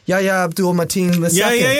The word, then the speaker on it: Yahya Abdul-Mateen II: Yahya Abdul-Mateen II.